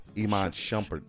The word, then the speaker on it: Shumpert: Is it Sharp? – Iman Shumpert.